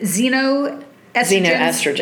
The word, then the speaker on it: xenoestrogens: I think you pronounce it xenoestrogens, even though it's spelled with an X.